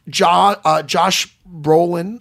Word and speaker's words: Josh Brolin: I loved Josh Brolin.